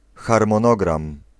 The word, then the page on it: schema: /ˈɧeːma/